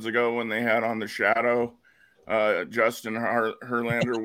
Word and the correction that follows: Hollander: which is Hollander. Excuse me, yeah. I can't say his name.